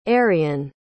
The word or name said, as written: arien